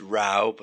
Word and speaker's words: row: and it's pronounced row, but spelled R-O-W like row, but pronounced row, to have a row.